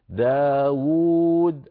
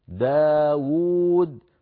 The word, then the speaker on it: داود: كذلك الأمر في كلمة داود في نفس الآية، في الآية 163 داود أيضا لفظها بواوين وكتبت بواو واحدة، فزاد علماؤنا بخط صغير واو ثانية ليبينوا للقارئ بأن هذه الكلمة تقرأ بواوين، داود الواو الأولى مضمومة والواو الثانية ساكنة مدية على وزن فاعول داود، فلا بد من نطق كلا الواوين ولو كانت في الخط القديم بواو واحدة كما أسلفت.